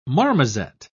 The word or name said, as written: marmoset